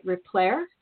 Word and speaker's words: Replere: I'm, i hope I'm saying it right. Replere, is that right? – Yes.